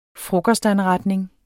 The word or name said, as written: frokostanretning